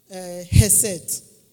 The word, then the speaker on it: Chesed: Okay, it's pronounced C-H-E-S-E-D, Chesed. – Oh, Chesed. – But it's pronounced Hesed.